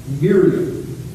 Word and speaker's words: Miriam: Miriam is the way it would have been pronounced in her world.